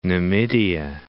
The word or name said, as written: Numidia